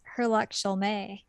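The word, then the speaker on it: Herlock Sholmès: Except for in that one, it's pronounced Herlock Sholmé. – Mm-hmm. – because they're like, oh, this is French, haul, haul, haul.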